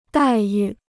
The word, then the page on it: 待遇: dài yù